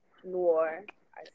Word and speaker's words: Nori: Well, my name is Nori. Nor. I say Nori for the people who can't say my name.